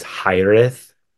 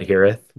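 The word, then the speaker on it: hiraeth: Heareth was the correct way to say that word is Hireth. Which is like so hard to say for some reason. But so we just call it Heareth, which is incorrect.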